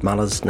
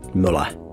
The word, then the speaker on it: Muller: We kind of got Todd Muller's name wrong. – Oh, cripes. Did I accidentally call him Simon Bridges or something? – No, we said Muller, when it should be Muller. Rhymes with fella.